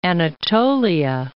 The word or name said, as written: Anatolia